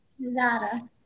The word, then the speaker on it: Mazzotta: Mazzotta.